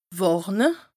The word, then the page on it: wàrne: Bas-Rhin; Strasbourg